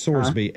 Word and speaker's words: Sorsby: Is it Sorsby or Sorsby? I've Sorsby. – Heard both pronounce this. – It's Sorsby. S-O-R-S-B-Y-S. Sorsby.